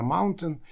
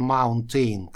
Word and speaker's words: mountain: Здесь это слово носители очень интересно произносят. Они не говорят mountain. И, конечно же, не говорят mountain, как некоторые произносят. Нет, это неправильно. Они делают так. Mountain. Mountain. То есть они, по сути, выкидывают звуки NT, потому что в современном английском букве T война объявлена, и после U делают небольшую такую паузу с придыханием, после чего говорят N.